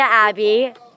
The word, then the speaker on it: Abbe: My name is Talia Abbe, T A L I A A B B E, and my reality is staying home with my friends.